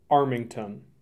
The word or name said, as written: Armington